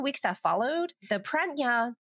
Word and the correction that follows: Pragyan: I'm not saying that right, Pragyan,